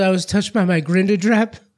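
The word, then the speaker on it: Grindadráp: Is that how they pronounce it there? – Grindadrap. – Looks like Grindadrap. – Or grind. Grindadrap. I immediately went with like Grindelwald.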